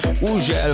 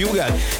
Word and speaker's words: Hugel: Így van, az úriember, akinek minden országon másképp ejtik a nevét. Van, ahol Uzsel, van, ahol Hügel, van, ahol Ugel, meg mindenféle kép, de nálunk úgy mondjuk, hogy Hugel, és Szálltó, és a Jamaican Bam Bam érkezik, a csavarjátok fel a hangerőt.